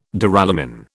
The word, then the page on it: Duralumin: British English